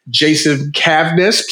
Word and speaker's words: Jason Cavness: I have a special guest here today, Jason Cavness.